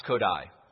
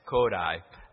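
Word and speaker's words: Kodály: Spelled Kodali, right, but pronounced Kodai, you know, and it's Kodai.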